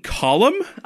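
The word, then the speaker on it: Colm: Fucking Colum? – I know!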